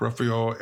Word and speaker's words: Ruffalo: Mark Ruffalo, Edward Norton, and Lou Ferrigno. – Who? – Who's the first name? Rufio? – That's Ruffalo. It rhymes with Ruffalo. Buffalo.